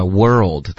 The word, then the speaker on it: world: OK，注意一下 world 的发音，要分成，最好是你自己练习的时候分成两个音节 world。 OK，你刚刚说的是 world。